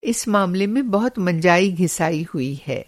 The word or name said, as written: मँजाई घिसाई